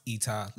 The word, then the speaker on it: ITA: but it's pronounced Eta Leisure.